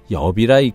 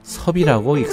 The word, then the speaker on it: 섭: 섭천사의 성, 섭은 잎사귀를 뜻하는 잎사귀 엽과 한자가 같으나 성을 지칭할 때는 엽이라 읽지 않고 섭이라고 읽습니다.